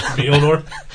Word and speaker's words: Mjolnir: And, Mjolnir? – I still can't spell Mjolnir. I know there's a silent J in there somewhere, but that's all I know.